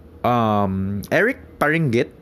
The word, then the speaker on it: Paringit: Eric Paringit. Yeah. Sorry for saying it in another language. It's supposed to be pronounced that way.